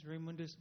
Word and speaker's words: Raymundus: Hi, my name is Raymundus Butar, R-A-Y-M-U-N-D-U-S, last name B-U-T-A-R, B-U-T-A-R.